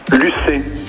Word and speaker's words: Lucay: Comment je prononce votre prénom? – Là, Lucie. – Lucé, et ça s'écrit L-U-C-A-Y.